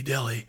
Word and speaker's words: Delhi: So the place where most of this book is set is a town in Louisiana that's spelled D-E-L-H-I, like the Indian city, Delhi. But how is it pronounced? – It's pronounced Delhi.